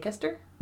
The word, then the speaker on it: Leicester: And I don't know what's up with the spellings of certain things in England, but yes, Leicester is spelled L E I C E S T E R. – Lake Leicester? Yeah. – Okay. It's Leicester.